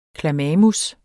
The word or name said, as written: klamamus